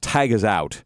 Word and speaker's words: Taghazout: That has that last one was I'm hoping it's pronounced Tag Is Out. Tag TAGHAZOUT. Before